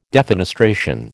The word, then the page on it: defenestration: American English